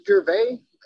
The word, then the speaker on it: Gervais: Erik, did I say your last name right? And how are you going? – Close enough. It's Gervais.